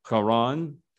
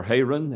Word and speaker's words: Haran: It occurred before Abram lived in Haran, or Haran, as it is sometimes pronounced.